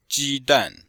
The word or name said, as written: ji1dan4